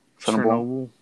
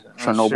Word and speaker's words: Chernobyl: How do you say it? – Chernobyl. Yeah, that's an American way of saying it. – So noble. – Chernobyl. Sure, you're saying it the right way.